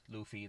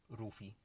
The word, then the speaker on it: Luffy: No, it's Luffy. They always say it Rufy in the Japanese version.